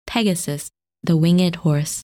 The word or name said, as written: Pegasus